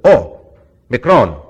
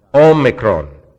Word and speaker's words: omicron: Now this is a long O, and it's drawn out a bit. Remember the 15 was a short O. This is O. Omega. Okay? The other is O. Micron. It's a little one. Just kind of pops out. Omega. Omicron. Excuse me. Omega. Omicron. O and O.